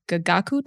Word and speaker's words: Gagaku: It's actually an anecdote you share about your conversation with a famous court musician in the Gagaku tradition.